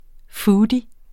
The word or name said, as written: foodie